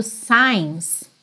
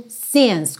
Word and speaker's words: since: E realmente esse é o uso mais frequente da palavra since, que em alguns sotaques pode ser pronunciado signs, tá bem? Então, se você é, talvez estiver numa região, viajar para uma região em que as pessoas digam signs, é possível também, mas não é a mais frequente. A pronúncia mais comum é mesmo since, com o i mais fechadinho.